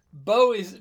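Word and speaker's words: bó: Bo is cow with a little accent on it. So it's a long O.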